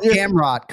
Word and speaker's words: Gamrot: So you got Gamrot correct.